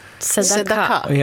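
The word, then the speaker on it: Tzedakah (צדקה): Tzedakah? – Ja, kanske vill uttala det lite bättre än vad jag gör.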